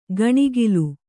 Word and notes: ಗಣಿಗಿಲು: gaṇigilu

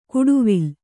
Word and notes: ಕುಡುವಿಲ್: kuḍuvill

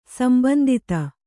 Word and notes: ಸಂಬಂದಿüತ: kannada

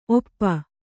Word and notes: ಓಪು: ōpu

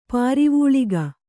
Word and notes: ಪಾರಿವೂಳಿಗ: Kannada

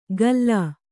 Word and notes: ಕಲ್ಲ: Kannada

